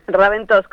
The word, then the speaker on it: Raventós: Es la primera vez que lo pronuncian bien, Raventós, con acento en la O, sí.